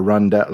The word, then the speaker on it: rondel: There's sort of like a rondel. Like, is that how you pronounce it? – Rondel.